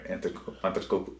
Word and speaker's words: Antetokounmpo: I'm going to say it right. Antetokounmpo.